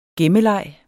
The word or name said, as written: gemmeleg